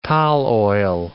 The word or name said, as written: tall oil